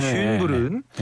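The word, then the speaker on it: Schönbrunn: 신년 음악회가 비싼 티켓을 구입해 턱시도와 드레스를 입고 듣는 음악회라면 쉔부른 여름밤 음악회는 일반 대중을 대상으로 하는 무료 음악회입니다. – 전 쉰부터 갈수 있는 줄 알고. – 이게 발음이 어렵습니다. 쉰부른인데 쉰이 될 수도 있어요. 쉰부른.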